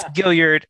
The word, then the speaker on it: Gilliard: A hard G, Gilliard. – Ah, dang yep. it. So the reason I went with Gilliard is because I'm listening to the audio version and the narrator said Gilliard at the beginning and I was like, yeah. Oh, that's got to be it. – No, it's Gilliard. And okay. – That would be the French way to pronounce it.